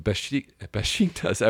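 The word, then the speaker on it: Beşiktaş: And it's great with Besiktas.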